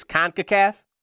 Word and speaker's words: CONCACAF: I think it's CONCACAF.